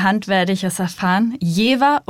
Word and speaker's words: Jever: Die Stadt Jever, so sagt es zumindest Wikipedia, wird ausgesprochen mit einem stimmlosen F wie Cuxhaven, Bremerhaven, obwohl mit V geschrieben. Und bei dem Bier, da wählt man eher das stimmhafte W.